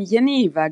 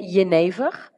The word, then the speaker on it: Genever: Da sagst du nämlich auch schon das, wo ich gerne im ersten Teil unseres Interviews hingehen würde, nämlich auf ich habe jetzt immer Geneva gesagt, aber du hast jetzt so schön Geneva gesagt. Ist das die korrekte Aussprache? – Na, in, in den Niederlanden sagen wir Geneva.